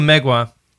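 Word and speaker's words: Mgła: Is it Mogwai? – No, Megwa. – Where are we? Oh, the black metal band. It's spelled MGLA.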